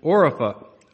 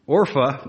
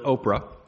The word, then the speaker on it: Orpah: "They lifted up their voices and wept again, and Orpah," my wife wanted me to make sure I pronounced this properly, "Orpah," too many people pronounce it Oprah, "kissed her mother-in-law, but Ruth clung to her."